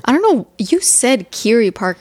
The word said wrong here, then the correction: Kerry Park: earlier today. And I was like, you mean Kerry Park, right? It's definitely Kerry Park. – Okay. I don't know why I always. – It's spelled like Kerry, like the name Kerry.